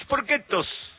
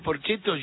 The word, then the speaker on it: Porchetto: Raúl Sporquetos. Y claro, lo pronunciaba en italiano. Y me causaba mucha gracia. Entonces, cada vez que escucho a Raúl Porchetto, yo enseguida en mi mente, Raúl Sporquetos.